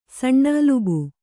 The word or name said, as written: ಸಣ್ಣಅಲುಬು